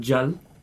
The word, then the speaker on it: Jal: And so basically it was written by a band called Jal. – How do you spell that? – A J-A-L.